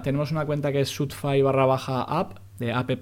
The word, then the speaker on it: Shootfy: Eh, Sotfi, mucha, la gente dice Sotfi, porque es verdad que es, eh, como se escribe, es S-H-O-O-T de Tarragona, F y Y.